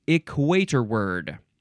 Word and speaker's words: equatorward: It is spelled equator ward. One this is one word, equator and then W A R D at the end, but it's pronounced equator word.